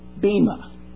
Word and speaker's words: bema: The word judgment seat is a translation of a Greek word, B-E-M-A. Most English pronunciations is bema. The E, though, is an eta in the Greek language, and it's rightly pronounced bema seat.